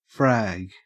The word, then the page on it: frag: Australian English